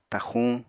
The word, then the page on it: taxuñ: apatani